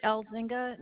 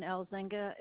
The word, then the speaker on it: Elzinga: This is Carrie L. Zynga, and it's spelled C-A-R-R-I-E, and L. Zynga is E-L-Z-I-N-G-A.